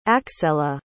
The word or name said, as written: axella